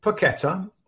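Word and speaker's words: Paquetá: But Lucas Paqueta, I think is actually the correct pronunciation, but I'm sure it's going to get butchered for a few weeks from all over the place.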